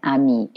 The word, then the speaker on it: amis: J apostrophe A-I, and then D-E-S, that's the day part, and then ami, A-M-I-S, ami. We don't pronounce the S when it stands alone like that because there's no E on the end, right?